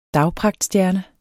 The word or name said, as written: dagpragtstjerne